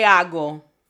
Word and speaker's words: hago: A-M-O space L-O space Q-U-E space H-A-G-O. Okay, primeramente, la H es silenciosa. H is silent. No one walks around saying, hola.